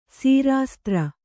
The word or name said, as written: ಸೀರಾಸ್ತ್ರ